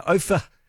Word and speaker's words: Ophir: This is between Bendigo and Ophir, and that's how you say it, not Ophir, Ophir.